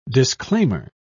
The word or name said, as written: disclaimer